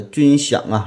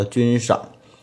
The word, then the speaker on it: 军饷: 还有呢，这个军饷啊，这个饷字我读成了军赏。